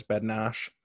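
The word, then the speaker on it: Bednář: Jan Bednar, however you want to pronounce it. I think the Czech pronunciation is Bednash.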